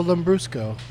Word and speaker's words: Lambrusco: I'll have a million different kinds of red wine and no Lambrusco. – Lambrusco.